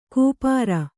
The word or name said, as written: ಕೂಪಾರ